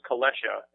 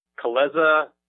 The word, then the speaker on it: Kalesha: It's pronounced Kalesha, and it's Polish.